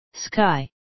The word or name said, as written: sky